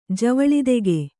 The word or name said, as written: ಜವಳಿದೆಗೆ